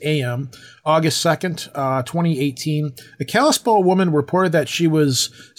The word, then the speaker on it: Kalispell: Is that word Kalispell? Is that how that's pronounced? – Yep.